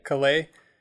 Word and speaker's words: Calais: Calais? How do you say that? – Calais? – Calais.